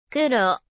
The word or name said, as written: kuro